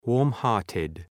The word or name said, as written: warm-hearted